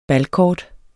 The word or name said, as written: balkort